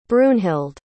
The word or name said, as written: brunhild